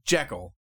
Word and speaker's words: Jekyll: Jekyll. Saying it right. You tell me to sound it out. – It's Jekyll.